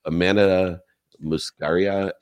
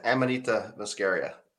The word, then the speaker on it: Amanita muscaria: It's a Amanita muscaria extract. – Close, yeah. Amanita muscaria.